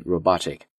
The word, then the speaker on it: robotic: 那么在读这个单词的时候注意一下前面的这个 O 它既可以发成 robotic 就发它的字母音也可以发这个音 robotic 因为重词的重音 robotic 是在这个 BO 上面 O 这个音